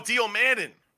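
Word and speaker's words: Dio Maddin: Dio Mudan. – Oh, Dio Madden. – Madden, thank you.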